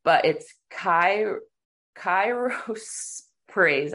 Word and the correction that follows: chrysoprase: I can't say it. – Oh, chrysoprase. – Yes, that's how I chrysoprase. – That's how I've always said it, but there's no PH at the end.